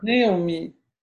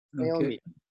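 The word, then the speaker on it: Naéomie: Naomi, N-A-E, accent aigu, O-M-I-E. – Can you write it down? – That three syllables or four? – Naomi. – Naomi. – Okay. Naomi. Three.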